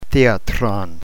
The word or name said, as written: theatron